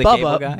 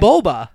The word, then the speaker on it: Boba: No, Larry not the Bubba. Boba.